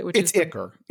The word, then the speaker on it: ichor: and I was thinking Ichor or Ichor, depending on how you pronounce it. – Ichor. It's 100% Ichor.